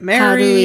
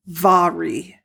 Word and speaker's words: Mhairi: And Mari spelled M-H-A-I-R-I. And we were talking about, was it Mary? Is it Mahari? How it? – Do you pronounce it? It's actually pronounced Vari.